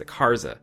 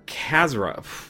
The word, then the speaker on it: Khazra: His farm was later attacked again by the creatures known as the Karza, led by Dark Cults. Khazra.